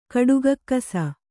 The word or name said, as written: ಕಡುಗಕ್ಕಸ